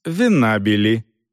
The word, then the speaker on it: Венабили: «Дорс Венабили», — старательно выговаривая буквы, повторила женщина, сделав подчеркнутое ударение на втором слоге фамилии.